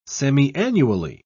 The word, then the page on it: semiannually: British English